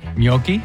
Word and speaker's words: gnocchi: Gnocchi.